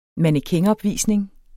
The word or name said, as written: mannequinopvisning